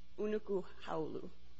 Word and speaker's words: Unuakhaulu: It's difficult to pronounce. It's UNUAKHAULU, I believe. Unuku Haulu.